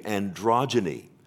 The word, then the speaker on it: Androgyny: Androgyny. – Thank you. Androgyny.